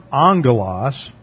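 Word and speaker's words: angelos (ἄγγελος): Now, the strength of this is that, first of all, the term angelos . That double gamma is pronounced NG.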